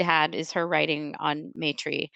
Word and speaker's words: Maitri: People pronounce it both Maitri and Maitri because of the way it's spelled, Maitri. So I've heard both. I'm no expert in phonetics, especially Sanskrit phonetics. So right. I'm going to say, you're right.